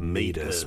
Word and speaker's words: meatus: It's just kind of close, at that point, when it gets to the end at the meatus, which is spelled M-E-A-T-U-S, so it's spelled meatus, but it's pronounced meatus.